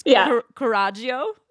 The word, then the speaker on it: coraggio: It's coraggio.